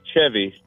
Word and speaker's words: Cheve: It is Chevy. – Chevy. Not Chevy. See, when I see it spelled out, it's C-H-E-V-Y, correct? – Yeah, C-H-E-V-E.